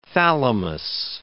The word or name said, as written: thalamus